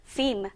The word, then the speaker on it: film: 영어 발음은 film. Film, 거의 이 L 사운드가 나지 않는다라고 생각을 하시면 됩니다.